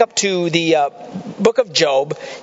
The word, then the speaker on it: Job: It looks like Job to us, J-O-B, but it's pronounced Job.